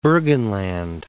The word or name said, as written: Burgenland